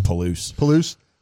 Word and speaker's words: Palouse: The Toulouse? – He was the real deal. – Palouse. – I said it wrong.